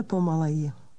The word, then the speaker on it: pomaleji: Prosím, mluvte pomaleji. Prosím, mluvte pomaleji. – Here's more slowly. It's one word. Remember that the stress in Czech is always on the first syllable.